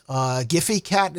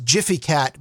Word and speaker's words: Gfycat: Image hosting site GFY Cat, Giphy Cat is how I've always pronounced it. According to Wikipedia's pronunciation guide, it's pronounced Giphy Cat, but they probably don't even know how to pronounce the word GIF.